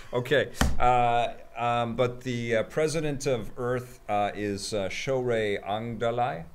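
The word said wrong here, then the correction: Aghdashloo: How do you say her name? – Oh, you're way off. – Shorey Agdasalu. – I'm going to try Agdasalu. – Now. – Okay. – Shorey Agdasalu. – Agdashlu.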